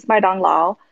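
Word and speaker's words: Maidong Lao (麦当劳): Like in Chinese, McDonald's is Maidong Lao.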